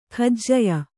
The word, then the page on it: ಕಜಿಯಾ: kajiyā